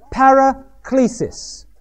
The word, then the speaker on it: paraklesis: P-A-R-A, para, K-L, long E-S-I-S. The word paraklesis.